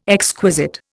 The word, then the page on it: exquisite: Indian